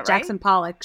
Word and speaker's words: Jackson Pollock: Some of my work looks like, hope I get his name right, Jackson Pollard. – Yeah, Jackson Pollock, sure.